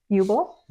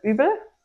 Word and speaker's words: Uebel: In German, you pronounce it Uebel, but that's a train wreck here. – Yeah. – So when we moved here, we wrote down our name, showed it to some friends, and we're like, how would you pronounce this? And it kind of came more or less to a consensus, and that's kind of what we stuck with.